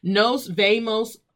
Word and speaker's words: nos vemos: Because in Spanish, speaking with that swag, you pronounce the V's like B's. This is not nos vemos. Nos vemos pronto.